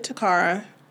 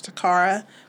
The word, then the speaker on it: Toccara: And her name is spelled T-O-C-C-A-R-A. – Mm-hmm. – You can also find her on Facebook. Tell them where your Facebook is. – Facebook, you can find me under Exceptional Creations with Toccara.